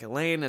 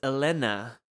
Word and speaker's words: Elena: And she was like, I was like, Elaine, and her name is Elena, because that's how it's pronounced in Greek.